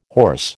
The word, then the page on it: hoarse: American English